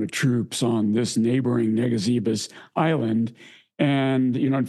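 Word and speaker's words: Ngesebus: But, the 5th Marines go off, and we talked about this just very, very briefly, I think, in episode one, because it's an island that's hard to pronounce. It's called pronounced Negazivus.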